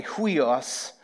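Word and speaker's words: huios (υἱός): So, for example, in the preface to the ESV Bible, it talks about this word family, huios, is how it's pronounced.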